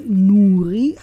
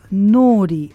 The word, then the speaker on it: Nouri: Geschrieben wird mein Vorname N-O-U-R-I. Hierbei wird das U allerdings nicht gesprochen. Ich hatte immer gedacht, das wäre Nuri, aber das U wird nicht gesprochen. Tatsächlich wird der Name also Nori ausgesprochen.